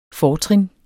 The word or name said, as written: fortrin